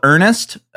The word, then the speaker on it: Earnist: Yeah, so we did it's called Earnest, but it's spelled Earnist. You know, sort of like a Web 2.0 play on the word Earnest.